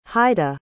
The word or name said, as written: heide